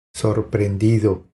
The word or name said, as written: Sorprendido